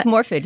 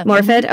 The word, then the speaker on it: Morfydd: You see, and you'll have to help me with the pronunciation of this brilliant actress's name, Morfydd Clark. – I think it's Morfydd, yeah. – Morfydd, okay.